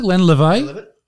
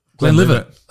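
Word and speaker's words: Glenlivet: Is that how you pronounce it? Glenn Levay? – Glenn Livett.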